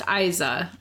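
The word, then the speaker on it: Eiza: And I think it's pronounced Iza.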